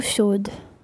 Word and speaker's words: chaude: Eau is feminine, so chaude, you pronounce the D because it's got an E on the end.